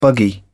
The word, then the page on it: buggy: British English